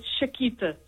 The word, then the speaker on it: Chiquita: It's Chiquita. – Oh, Chiquita. – Chiquita. Okay. Yes. Spelt with a C-H, though. – Yes, C-H-I-Q-U-I-T-A.